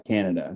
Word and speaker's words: Kaneda: So there's Tetsuo and Kaneda. Mm-hmm. Is it Canada or Kaneda? Either one. Kaneda. In the movie, I watched the dub version. No, I watched the subtitle version. They pronounce it Canada.